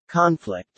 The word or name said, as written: conflict